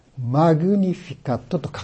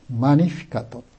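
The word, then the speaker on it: Magnificat: これのラテン語がマニフィカット、マグニフィカットと書きますけれども、教会のラテン語ではこの G の音を発音しないので、マニフィカットと言いますけれども、でここから英語のマグニファイという言葉が生まれましたね。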